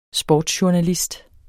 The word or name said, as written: sportsjournalist